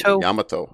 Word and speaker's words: Yamato: Is Yamato from Naruto Shippuden. I hope I said his name right. – Yamato.